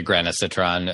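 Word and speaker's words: granisetron: Or D, a drug that I don't really know, but I'm going to guess, Granicitran, which is called Tritran. How do you say it? – Yeah, I'm not sure. I'd say granacitran as well.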